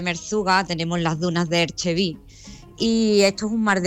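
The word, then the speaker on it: Erg Chebbi: Ajá, Erchevi se pronuncia, Manu.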